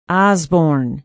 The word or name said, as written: osborn